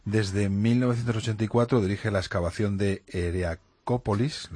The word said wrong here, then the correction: Heracleópolis: lo he dicho bien. – Heracleópolis. – Heracleópolis, Jesús.